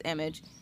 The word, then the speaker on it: Image: I'm making sure I'm not saying that wrong. Yeah, it's image.